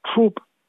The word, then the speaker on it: Troop: Para aquellos que no hablan inglés, Troop, Troop of Actors, el nombre de la compañía de Nitzi y Leopoldo, se escribe T de techo, R, O, O, P.